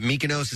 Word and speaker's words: Mykonos: Is it Mykonos? Is that how you pronounce it, Kathy? – Yeah.